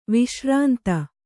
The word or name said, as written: ವಿಶ್ರಾಂತ